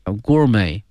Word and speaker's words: gourmet: For thousand years grilling has evolved from a primitive cooking form，它发生了一个演变哈，用这个词 evolve E-V-O-L-V-E 哈，这个词表示一个演化一个变化， from a primitive cooking form to sophisticated gourmet delicacy， gourmet G-O-U-R-M-E-T 后面这个 T 是不发音的， gourmet 表示美食的一个 delicacy 哈。